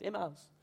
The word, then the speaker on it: Emmaus: Emmaus.